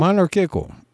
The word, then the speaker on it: monarchical: Even if Jefferson started the written form due to his social anxiety, I actually agree with him on the monarchial, help me say that. How do you say that? Monarchial. Say it for me. – Monarchial. – Well, that presentation. On a personal level. – Monarchical. Monarchical. – Monarchical. Monarchical. Okay.